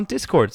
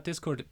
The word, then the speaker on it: Discord: Hop up on that Discord, baby. Benjamin, I've just discovered that it's pronounced Discord. – It's not. That's a lie. That's a lie.